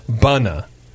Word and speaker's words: Bona: Bona, B-O-N-A, Bunna. – Now, that's just being... weird.